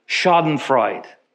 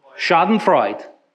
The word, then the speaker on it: Schadenfreude: Schadenfreude. Schadenfreude. One more time. Schadenfreude.